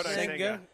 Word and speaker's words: Senga: Yeah, Senga.